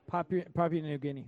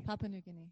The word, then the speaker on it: Papua New Guinea: Guinea pig, or I don't know how to pronounce this country's name. – Papua New. – Say it again. – Papua New Guinea. – Papua New Guinea.